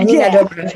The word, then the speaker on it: Nina Dobrev: Nina Dobrev. – Mm-hmm. – I think that's how you pronounce it. – Yeah, you do. Yeah, Nina Dobrev, yeah.